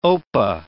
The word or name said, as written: opah